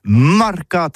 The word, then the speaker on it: marcat: c'est prononcé sur les marcat, avec un A.